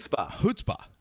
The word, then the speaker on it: chutzpah: So, Gladwell talked about Chumspa. Am I saying that right? – Chutzpah.